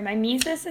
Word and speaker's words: mimesis: So it's the, sorry, mimesis it's pronounced? – Yeah.